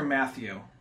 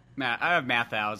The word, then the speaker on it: Matthau: I was hoping for more of a dynamic between Walter Matthau or Walter Matthew? – Matthau is how I think how it's always pronounced.